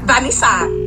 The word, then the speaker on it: Vanessa: That's how they say my name in French. Vanessa.